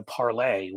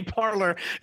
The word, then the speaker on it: Parler: he'll just move over to Parlay, where all the conservatives are now going. – Say Parler. It's cute. You pronounce it in French. They say Parlay. We ought to call it Parlay, though.